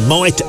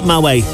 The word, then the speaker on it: Moët: Killer Queen, and of course, that was where I learned how to pronounce the name of the popular champagne, Moët Chandon. That's not Moët.